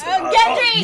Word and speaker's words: Gendry: It almost sounds like laundry. – Yeah, yeah. – Oh, it's Gendry.